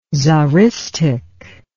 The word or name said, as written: tsaristic